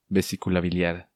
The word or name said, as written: Vesicula Biliar